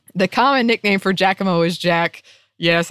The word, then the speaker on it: Giacomo: Every Italian will argue that their dialect and region are the correct ones, but I've come to learn that the pronunciation is more like Giacomo. Now, don't say it too fast. The O is drawn out to a long O sound. oh, now I'm in my head. Um, sometimes the Jack part sounds just like Jacques with the J sound.